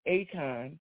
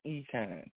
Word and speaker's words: Achan: It's spelled A-C-H-A-N. So at first I believed it was, I was saying Akon, but then I looked it up, and the pronunciation is Econ.